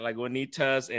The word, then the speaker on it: Lagunitas: I said it right, Lagunitas, and this thing is called Little Something Supping Ale.